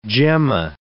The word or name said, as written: gemma